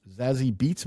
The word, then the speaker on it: Zazie Beetz: Tom Felton, Heather Graham, Zazie Beetz. Am I saying that right? Mm-hmm.